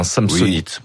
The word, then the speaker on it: Samsonite: Vous le prononcez à la française, hein, Samsonite. – Oui, bon, je vais suivre votre prononciation.